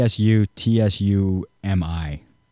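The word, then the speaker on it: Tsutsumi: It's T S U T S U M I Sakamoto. Susumi Makamoto? – Sutsumi. – Okay, Sutsumi Makamoto.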